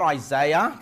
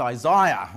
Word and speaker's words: Isaiah: Not Isaiah, that's how Americans say it. It's Isaiah, okay? We're in Australia.